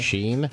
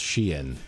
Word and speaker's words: Shein: Apparently Shein is like she and Sheen. – Okay. – Sheen? – Yeah, so they had this It's pronounced Sheen.